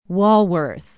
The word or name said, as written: walworth